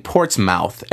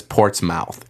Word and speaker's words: Portsmouth: But when it comes to saying Portsmouth, every American says Portsmouth. It's not Portsmouth, it's Portsmouth. Fair enough. It's like places with ham at the end. You know, it's a silent H, so instead of Nottingham, it's Nottingham, or Rotherham, or Birmingham.